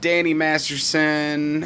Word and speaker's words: Masterson: We're going to finish this segment off today with talking about Danny Masterson. Yeah, that's how you say his name, right? Yeah.